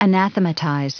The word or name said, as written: anathematize